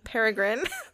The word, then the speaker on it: Peregrine: I'm talking about peregrine. It's P-E-R-E-G-R-I-N-E. – Peregrine. – Yeah, there we go. Peregrine.